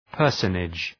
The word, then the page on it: personage: ‘pɜ:rsənıdʒ